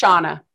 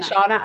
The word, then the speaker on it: Shana: It's Shana. That's how I say it. Shana, I know that that's going to be hard for you to say because yes.